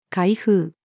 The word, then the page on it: 海風: female